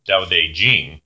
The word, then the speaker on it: Tao Te Ching: However, most people that I've researched in my vast amount of time studying this, which was longer than most sermons, is that it should be pronounced Tao Te Ching. However, other pronunciations are acceptable, and you can also see other spellings where it's spelled with a D instead of a T.